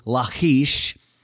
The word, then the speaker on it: Lachish: One was a stronghold called Lachish, or it would be pronounced in the Hebrew, Lachish, and then another one called Azekah.